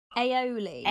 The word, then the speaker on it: aioli: I said that wrong. I always say that word wrong. It's not ali. I say alioli. That's wrong aioli. – Aioli, but I've once called it arioli, and that's the nipple.